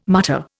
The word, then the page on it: mutter: Indian